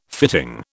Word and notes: fitting: British English

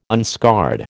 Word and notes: unscarred: American English

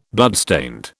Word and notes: bloodstained: British English

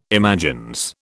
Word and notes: imagines: British English

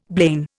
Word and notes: blain: Indian

